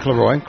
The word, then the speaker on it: McIlroy: And Rory McIlroy, did I say that right? – McIlroy, close enough.